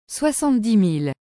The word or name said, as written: 70000